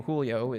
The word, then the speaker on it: Julio: I didn't look a lot like my fellow classmates, and it was made a little bit more complicated by the fact that my name, Julio, is spelled with a J, however, you don't pronounce it like you would pronounce it in English.